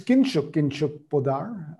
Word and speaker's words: Kinshuk Poddar: Today's guest is Kinshuk Poddar. I hope I've got the pronunciation to that right. Have I, Kinshuk? – You have. Perfect.